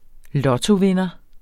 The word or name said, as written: lottovinder